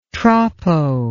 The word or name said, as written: troppo